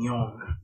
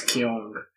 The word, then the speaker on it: Young: As the name keeps evolving every day, and for ease of pronunciation, people decide to rhyme it as Kyung.